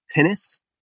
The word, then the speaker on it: pinnace: The one that you mentioned specifically, pronounced pinnace.